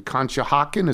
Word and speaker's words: Conshohocken: from Sean Morrissey in Conshohocken.